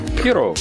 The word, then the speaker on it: puros: Linda's Spanish word of the week is puros, which means pure, pronounced puros. Sounds like boo and row.